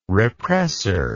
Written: represser

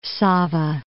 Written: Sava

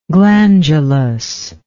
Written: glandulous

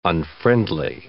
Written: unfriendly

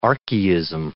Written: archaism

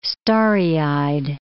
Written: starry-eyed

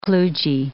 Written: kludgy

